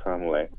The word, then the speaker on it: Tamale: It's spelled like Tamale, but it's pronounced Tamale.